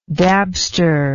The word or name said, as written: dabster